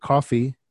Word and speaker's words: Koffee: It's by an artist named Coffee, but Coffee is spelled with a K. – Okay, K-O-F-F-E-E, featuring Gunna.